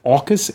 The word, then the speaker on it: AUKUS: I suppose we're supposed to pronounce it AUKUS, Australia, UK, AUKUS, US AUKUS alliance.